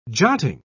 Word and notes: jotting: British English

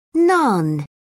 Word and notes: naan: British English

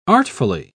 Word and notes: artfully: British English

